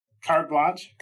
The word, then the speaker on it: Carte Blanche: We are excited today to discuss your upcoming book, Carte Blanche. Is that how you say it? – Is it French? I think it's French. – Carte Blanche. – There you go.